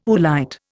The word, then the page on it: oolite: Indian